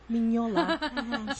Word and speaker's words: Mignola: Mignola. – Mignola.